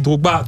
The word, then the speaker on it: Drogba: Voilà, on dit, on dit Drogba, tout simplement.